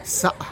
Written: za

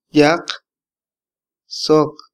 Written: yaq sok

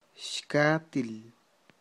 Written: xkatil

